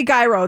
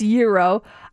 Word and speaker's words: gyro: People say gyro, but is it gyro? – It's gyro. Yeah. Oh. If you say gyro, that is incorrect. It's gyro. You go to a smoothie shop and that purple bowl.